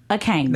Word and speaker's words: Akami: If the world can say Rihanna, the world can say Akami.